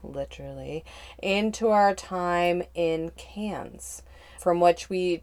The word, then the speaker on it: Cairns: That's right, it's pronounced Cairns, people, not Carnes.